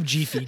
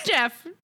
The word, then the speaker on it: Jeef: And someone who works on this show is named Jeef O'Brien. – Jeef. Good old Jeef. I love Jeefy. Jeefy O'Brien. – J-E-E-F. Jeef. Not Jeff.